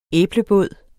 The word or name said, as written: æblebåd